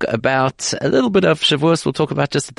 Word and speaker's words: Shavuos: It's Thursday afternoon, fresh thinking time, as we prepare ourselves not only for a Shabbos this week, but for a big event, the festival of Shavuot, or as I like to pronounce it, as many people like to pronounce it, Shavuos.